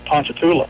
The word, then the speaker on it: Ponchatoula: Amit is where the story's coming from, but where the church was Ponchatoula.